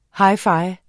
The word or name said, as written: hi-fi